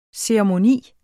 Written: ceremoni

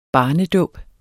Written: barnedåb

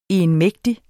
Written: egenmægtig

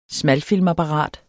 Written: smalfilmsapparat

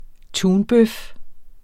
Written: tunbøf

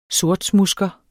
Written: sortsmudsker

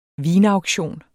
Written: vinauktion

